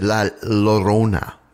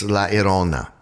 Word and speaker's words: La Llorona: I had always pronounced it La Llorona. I had pronounced the L's, but apparently I was being very sort of Anglo-Saxon about that. It's La Llorona.